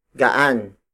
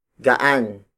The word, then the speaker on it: gaan: Gaan. Gaan. Gaan is spelled as G-A-A-N.